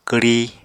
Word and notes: kwri: Apatani

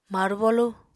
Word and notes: marbolu: apatani

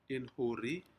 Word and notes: inhuri: Aneityum

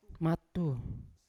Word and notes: matu: Yawa

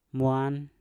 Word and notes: mwan: Mpur

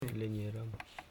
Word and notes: linye: li.nye

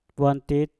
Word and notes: bwantik: Mpur

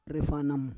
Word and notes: refanam: Roon

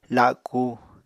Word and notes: laku: apatani

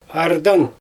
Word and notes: ardang: Xyzyl

